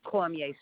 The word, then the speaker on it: Cormier: Cormier, C-O-R-M-I-E-R. – C-O-R-M-I-E-R? – That's right. – Okay. And you saw Diane's D-I-A-N-E, correct? – Diane, D-I-A-N-E, right.